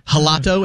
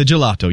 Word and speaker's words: gelato: And I thought it was like a soft G, like you might pronounce a J in Spanish. So I was like, I'll have the gelato.